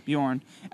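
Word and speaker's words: Björn: Bjorn? Is it Bjorn? – Bjorn.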